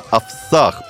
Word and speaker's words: овсах: В комитете пояснили, что самой популярной является охота на овсах. Правильно ударение? – Да. – Да, то есть от слова «овес».